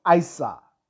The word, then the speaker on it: Isa: In these languages, the name is pronounced Isa, meaning ice or water, in its most passive, crystallized negative state.